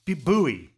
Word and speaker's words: buoy: Yeah, so buoy, B-U-O-Y. It's a funny spelling, right? Yeah. In North America, it's pronounced buoy. Think of the thing in the water that marks sort of territory or areas in the water.